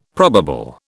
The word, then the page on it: probable: British English